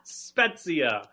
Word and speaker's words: Spezia: Because I don't know how to pronounce Spezia. – Spezia.